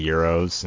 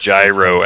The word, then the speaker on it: gyro: No one's got that many euros. Impossible. No way. That's just too many euros. What in the world? You can't get that many euros. – It's not euro. It's, I think it's pronounced gyro, actually.